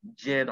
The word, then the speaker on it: Jin: There's a couple other songs by Jin. I hope I'm saying that name right. – You are saying his name right. You're good.